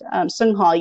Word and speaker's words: Songha: And if anyone has seen my name, it's pronounced Sungha, yes, but the actual lettering of it is set with Songha, which trips people up a Yeah.